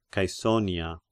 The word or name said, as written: Caesonia